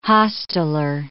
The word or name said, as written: hosteler